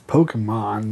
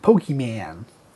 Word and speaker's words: Pokémon: What is this Pokemon man? – How do you pronounce it? – I pronounce it Pokemon.